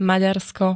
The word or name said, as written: Maďarsko